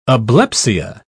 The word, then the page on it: ablepsia: British English